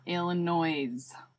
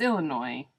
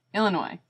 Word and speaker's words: Illinois: Can I just put a PSA out there that it's not Illinois? Please stop calling it Illinois. It's Illinois. Illinois. No S at the end.